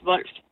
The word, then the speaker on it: Volf: Så da man under krigen ligesom gerne vil være mere dansk, så ændrede de faktisk navn til at hedde V-O-L-F, og det udtales Wolf.